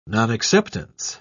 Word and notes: nonacceptance: British English

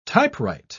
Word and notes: typewrite: British English

